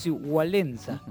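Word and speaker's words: Wałęsa: Me dijeron que se pronuncia de esa Walesa. – Manera. Sí, Walesa.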